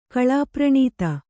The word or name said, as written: ಕಳಾಪ್ರಣೀತ